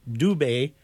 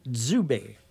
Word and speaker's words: Dubé: And that's D-U-B-E. In France, they would pronounce that Dubé. In Quebec, they pronounce it Zubé.